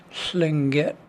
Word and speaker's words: Tlingit: That is Tlingit.